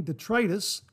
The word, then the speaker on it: detritus: Wash my face and hair, step out, shave, then back in to rinse off the shaving detritus and clean my body. Now, I'm almost positive of the pronunciation of that word, detritus. – Detritus. – There you go. I double checked it.